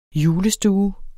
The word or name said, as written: julestue